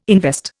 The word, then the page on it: invest: Indian